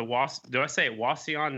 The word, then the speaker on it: Wauseon: It's the Wauseon National Vintage Flat Track on July the 19th.